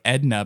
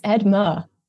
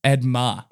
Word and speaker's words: Edma: I want to say like Edna, but it's not. – It's Edma. – Edma.